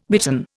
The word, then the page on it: bitten: Indian